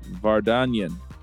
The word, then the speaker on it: Vardanyan: It's Vardanyan. – Yeah, you got it mostly right. Armenian words usually are pronounced as they are written.